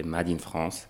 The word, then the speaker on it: Made in France: Mad, quand on prononce Made in France en français, ça fait Mad in France.